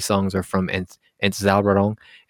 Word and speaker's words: Entzauberung: It's called Entzauberung.